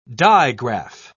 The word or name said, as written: digraph